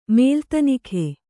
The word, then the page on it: ಮೇಲ್ತನಿಖೆ: Kannada